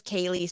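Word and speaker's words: Kayleigh: That is just my name is Kaylee, spelled with E-I-G-H.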